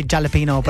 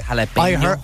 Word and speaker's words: jalapeño: See the accent on the N? – Yeah. – In Spanish, they call that the ñe. – Okay, so, yeah. – Jalapeño.